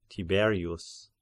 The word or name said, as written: Tiberius